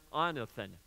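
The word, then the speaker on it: anothen (ἄνωθεν): It's pronounced anothen.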